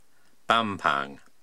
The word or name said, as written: Bampang